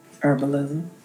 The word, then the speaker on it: herbalism: It's not herbalism. The H is silent.